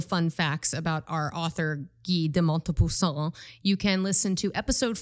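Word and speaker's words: Guy de Maupassant: Guy de Montepassant.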